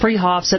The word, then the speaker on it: Fruhoffs: Spelled Fruhoffs, pronounced Freehoffs at 33rd and Arapahoe in Boulder.